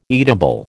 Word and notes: eatable: American English

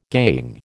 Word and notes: gangue: American English

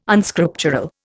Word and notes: Unscriptural: Indian

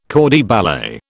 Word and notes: corps de ballet: British English

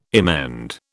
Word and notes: emend: British English

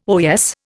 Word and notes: oyez: Indian

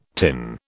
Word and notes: tin: British English

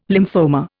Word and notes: lymphoma: Indian

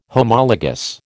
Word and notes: Homologous: American English

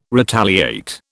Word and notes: retaliate: British English

